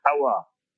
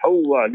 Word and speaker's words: Hawwa: So Nabi Adam and Hazrat Hawa, we say Nabi Adam alayhi salam, Sayyidah Hawa, is Hawa, H-A-W-W-A, not Hawa, it is Hawa, learn pronunciation properly.